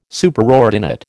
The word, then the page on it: Superordinate: American English